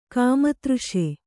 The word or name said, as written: ಕಾಮತೃಷೆ